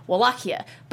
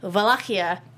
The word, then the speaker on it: Wallachia: And it's basically in English, it's Wallachia. But it's actually Wallachia in Romanian. – Oh. – Because in Romanian, you don't have a W. It's Germanic. It's a V. Wallachia.